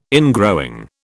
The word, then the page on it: ingrowing: British English